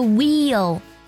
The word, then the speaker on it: Wheel: 最后呢，像 W-H 这个字母组合我们也比较熟悉了， H 不发音， W-H 发的是 W 的音，比如说 Wheel 轮子， W-H-E-E-L Wheel。